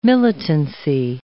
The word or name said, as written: militancy